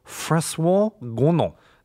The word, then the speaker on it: François Gonon: They were led by a neurobiologist by the name of François Gonon. That's the proper pronunciation. I'm French, so is I know it?